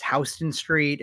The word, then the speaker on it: Houston Street: I mispronounced Houston Street. Apparently it's Houston Street.